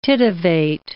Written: tittivate

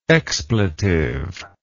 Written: expletively

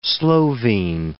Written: Slovene

